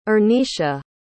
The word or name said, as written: Ernesha